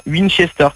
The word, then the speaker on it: Winchester: J-O-R-G-I-Y. – Non, G-Y-O-R-G-Y. – Ok. – Voilà. – Georgie. – Plus loin, Winchester, comme ça se prononce. – Oh là là. Tu auras trop les westerns, toi. – Non, W-I-N.